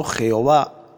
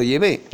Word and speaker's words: Yahvé: He de decir en primer lugar que en este libro el nombre falso Jehová lo he cambiado por Yahvé, ya que esta es la pronunciación más exacta del nombre de Dios en hebreo arcaico.